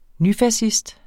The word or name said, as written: nyfascist